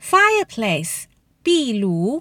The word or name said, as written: fireplace